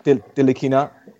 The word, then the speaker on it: Ntilikina: What are your thoughts on Frank Ntilikina? How do you say it? – Ntilikina.